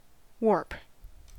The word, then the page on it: warp: American English